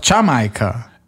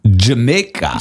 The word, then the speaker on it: Jamaica: Das heißt doch Jamaika. Jamaica. Jamaica ist natürlich, aber es heißt auf gar keine Fälle Jamaika. Nein, Jamaica.